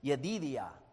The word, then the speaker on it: Jedediah: Well, that's the English pronunciation. But look at your notes. I really found this interesting. I'm going to try it. You have the Hebrew there. It's said like this, Yedidiah, Yedidiah.